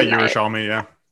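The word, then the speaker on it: Jerusalmi: Exactly, mom, Miriam Jerusalem, I'm going to mispronounce her last name. – Yeah, Yerushalmi, yeah.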